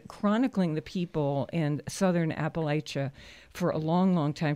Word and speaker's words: Appalachia: Either pronunciation is technically correct, but in the southern Appalachians, we say Appalachia.